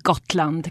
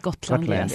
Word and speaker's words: Gotland: Yeah, let's talk about Gotland. Is that pronounced correctly? Gotland? – It's called Gotland. Gotland.